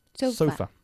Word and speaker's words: sofa: Y sofá en inglés, pues ponemos el énfasis en la otra sílaba. – Sofa.